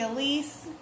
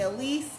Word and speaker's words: Alyse: My name is Tiffany Elise. A lot of people call me Tippy. My Instagram is at Tiffany Elise. A-L-Y-S-E. Tiffany spelled like the ring.